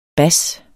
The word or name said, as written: bas